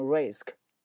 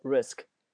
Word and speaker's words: risk: 看第二单词 risk， risk 表示危险冒险，很多学生在发这个单词发音的时候容易把中间这个 a 这个发音发成 a，发成 risk，没有那么夸张，它就是一个简单的 it 那个 a 的那个发音，就是 risk， risk。